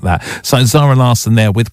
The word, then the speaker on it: Zara Larsson: It's Zara Larsson.